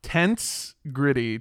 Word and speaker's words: Tensegrity: Tensegrity. Tensegrity? Tensegrity. It's spelled like tense and then gritty.